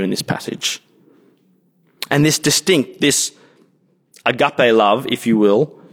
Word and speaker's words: agape: The word translated here as love throughout this passage is a word pronounced agape.